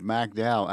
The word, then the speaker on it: McDowell: They used to call it the Free State of McDowell, accent on the first syllable.